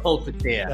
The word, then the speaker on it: Polpitiye: Thushara Polpitiye.